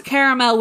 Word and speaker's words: caramel: No, as long as you pronounce caramel with three syllables, we're good.